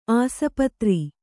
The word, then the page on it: ಆಸ್ಪತ್ರೆ: Kannada